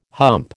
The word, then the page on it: hump: American English